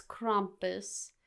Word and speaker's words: Krampus: Well, Krampus, not Krampus, like some people I've heard say his name, which is just like, oh, it sounds so bad. It's Krampus.